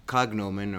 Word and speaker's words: cognome: There was a praenomen, which was their first name, a nomen, which was the last name, and a cognomen or cognomen, or in Italian it's cognome.